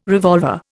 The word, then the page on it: revolver: Indian